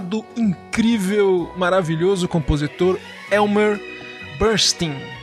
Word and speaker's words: Leonard Bernstein: E o Leonard Bernstein, pronuncia-se, no caso dele, Bernstein, era conhecido como o Bernstein do Leste.